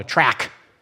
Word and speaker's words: tract: And then I remember being a young Christian in missions, and one day me and my buddy Jeremy found an evangelism tract, T-R-A-C-T. But you don't pronounce it as tract. I only did that for y'all. They call it a track. I don't know why.